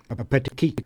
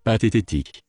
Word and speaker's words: Pathétique: Just before I played this tune, I took a crack at what I thought the pronunciation would be, a petit.